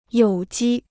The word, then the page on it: 有机: yǒu jī